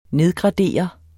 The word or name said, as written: nedgradere